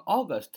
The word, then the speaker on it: August: 那么 August 为什么是八月呢？首先，A U 发音是 O，是吧？